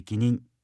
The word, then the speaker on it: 貴人: ことなしと書いて無事、尊い人と書いて気任と読むのですが、これは無事に日々を過ごすことがめでたく、そのようにできる人が尊い人という意味に思われがちですが、真の意味は若干違います。